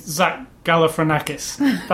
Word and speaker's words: Galifianakis: And I am not familiar with Zach Galifianakis.